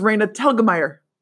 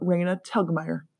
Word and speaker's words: Raina Telgemeier: Raina Telgemeier. It's kind of tricky to learn how to say her name, but Raina Telgemeier.